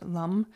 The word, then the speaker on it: Lam: So today, while we're singing Lum, it's just L A M, but it's pronounced Lum.